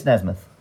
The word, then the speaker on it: Nesmith: Is it Nesmith or Nebit? I'm saying his name wrong. I think it's Nesmith.